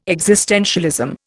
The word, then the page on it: existentialism: Indian